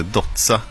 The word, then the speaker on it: Dozza: Ja, alltså man kan ju lyssna på dialekten, men man kan ju också tänka att han heter Dozza, som uttalas på samma sätt som pizza, stavas med två z och så en vokal på slutet också i efternamnet.